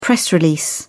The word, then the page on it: press release: British English